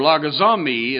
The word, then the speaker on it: logizomai: Well, that comes from the Greek word logizomai, or logizomai is actually the correct pronunciation, and it means to calculate or compute.